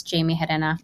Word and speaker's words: Jaymie Gerena: It's Jaymie Gerena.